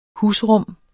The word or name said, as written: husrum